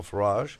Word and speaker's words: Farage: And I was hearing on the way in this morning that Farage, is how you say his last name? Nigel Farage? – Yes.